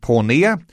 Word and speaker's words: Porneia: It's called Porneia. And it's spelt P-O-R-N-E-I-A. E-I-A.